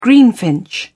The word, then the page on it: greenfinch: British English